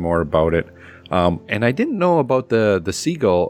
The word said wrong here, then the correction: sigil: Am I saying that right? Of course. – Sigil? – Sigil. – Sigil. – Sigil, yeah. Because that was a separate comic, right?